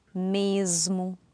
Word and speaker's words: mesmo: So rule number four is, if you have an S at the end of a syllable before a voiced consonant, it'll sound like a Z. And so a couple of examples here, would be words like? – Lisboa, Osvaldo, mesmo, desde.